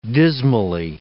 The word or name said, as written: dismally